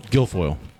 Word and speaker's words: Guilfoyle: The White House has asked Kimberly, how do you say her last name? Is it Guilfoyle? – Guilfoyle.